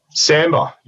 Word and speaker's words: sambar: We pronounce it sambar. It's kind of spelled sambar. If you're an Aussie, you'd say samba deer, as in the style of dance, but there's actually an R at the end. So it's S-A-M-B-A-R.